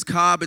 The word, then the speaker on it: Kab: It's spelled K-A-B. It's been butchered my whole life. People say cab, I answer to almost anything. People come up to me and say, oh, like corn on the cob or Cobb salad,